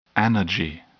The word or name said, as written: anergy